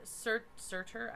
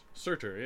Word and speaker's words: Surtur: After Surtur... I'm saying that wrong. – Surtur, yeah.